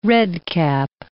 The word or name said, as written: redcap